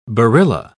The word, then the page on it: barilla: British English